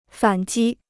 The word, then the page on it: 反击: fǎn jī